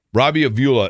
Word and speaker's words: Robbie Avila: And Jan pointed out to me that it's Robbie Avila. Robbie Avila is how you say it for Indiana State.